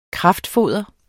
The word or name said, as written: kraftfoder